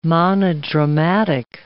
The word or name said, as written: monodramatic